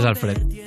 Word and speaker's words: Alfred: Solo lo leía, entonces decía, pues, acento en la A, por ejemplo, ¿no? – Bueno, eh, es Alfred.